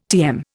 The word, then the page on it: TM: Indian